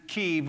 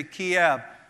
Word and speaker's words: Kyiv: I was in Kyiv, or you may say Kiev. One is the pronunciation of Russian, and the other one is in Ukrainian.